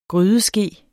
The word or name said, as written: grydeske